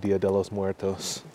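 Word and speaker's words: Día de los Muertos: I wonder how, like in Mexico, they have Dia de los Muertos.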